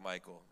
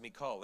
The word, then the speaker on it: Michal: By the way, I used to always say the name Michael, okay? That sounds like Michael, you know, but it, you confuse it with the guy, right? So, but it's actually pronounced Michal. Everybody say it with me, Michal.